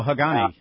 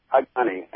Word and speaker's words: Hagane: Is it pronounced Hagane? – Oh, Hagane, yeah. – Hagane,